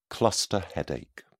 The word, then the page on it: cluster headache: British English